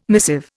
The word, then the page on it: missive: Indian